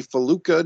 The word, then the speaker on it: Falluca: My buddy Jimmy Faluca.